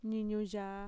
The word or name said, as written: niñuyaa